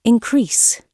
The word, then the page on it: increase: British English